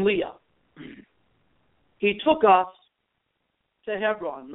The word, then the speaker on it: Hevron: You know, Christians call it Hebron. But the proper Hebrew pronunciation in Israel is Hebron, H-E-V-R-O-N. Now, Hebron is mentioned in the book of Genesis, where Abraham purchases the Machpelah cave to bury his wife, Sarah.